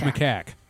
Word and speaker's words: macaque: Like, do you pronounce it macaque or macaque? – It's macaque.